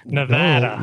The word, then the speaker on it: Nevada: Nevada. Oh, look at that. Said it right.